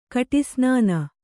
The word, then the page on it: ಕಡುಸನ್ನಿ: kaḍusanni